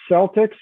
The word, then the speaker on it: Celtic: So is it pronounced Celtics or Celtics? – It's Celtic. Celtic without the S at the end.